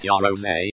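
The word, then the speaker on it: Yaroze: The Netyaros, pronounced Yarose, incidentally, costs £549, and allows you to program your own PlayStation games.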